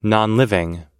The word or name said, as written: nonliving